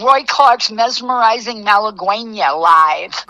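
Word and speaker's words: Malagueña: Malaguena.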